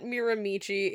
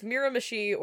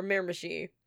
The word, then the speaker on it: Miramichi: But yeah, like, it's not Miramichi. It's Miramichi or Miramichi, if you're a true local. And, like, I get that the pronunciation doesn't really match the spelling, but that's because the Maritime increasingly names things after the indigenous Mi'kmaq language, and that's how it works.